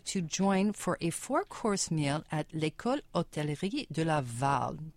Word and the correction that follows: École Hôtelière: Did I do that well? – I think it's École Hôtelière. – Hôtelière. Okay.